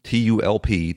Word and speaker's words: Tulp: This is from a guy named James Tulp, T U L P, Tulp, I guess it's pronounced, like tulip without the I.